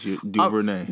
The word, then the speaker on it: DuVernay: DuVernay, right? DuVernay. DuVernay. Yes. Am I saying that right? – Yes, DuVernay.